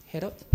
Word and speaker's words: Herod: Herod? – Herod.